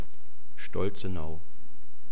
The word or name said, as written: Stolzenau